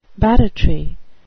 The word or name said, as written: barratry